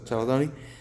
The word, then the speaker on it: chalcedony: Chalcedony.